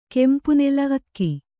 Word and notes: ಅಕ್ಕಿ: akki

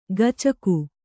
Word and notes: ಗಚಕು: gacaku; Kannada

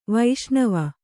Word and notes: ವೈಷ್ಣವ: vaiṣṇava

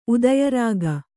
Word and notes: ಉದಯರಾಗ: udayarāga; Kannada

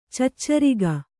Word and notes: ಚೆಚ್ಚರಿಗ: Kannada; ceccariga

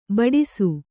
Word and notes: ಬಡಿಸ: Kannada

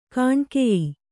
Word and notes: ಗೋನಿಕಾಯ: Kannada

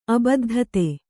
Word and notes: ಅಬದ್ಧತೆ: abaddhate

